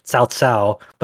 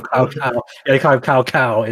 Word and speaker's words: Cao Cao: So you have a name like a CAO, that's pronounced like the proper pronunciation that would be Cao Cao, but then in the actual dub for Dynasty Warriors 3, they call him Cao Cao in it.